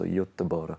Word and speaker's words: Göteborg: Actually, I got a pronunciation lesson as well from Fanny Sunesson, so Göteborg. – Close. – Is that close? The way you said close there suggested that was actually pretty bad.